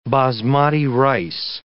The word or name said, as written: basmati rice